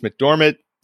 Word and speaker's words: McDormand: So I called you out on the bird, but now we're caught up and we see Frances McDormand's name.